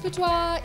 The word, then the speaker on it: boudoir: So it's like it's it's pronounced boudoir, even though it's spelled B O U D O I R comes from the French word to sulk.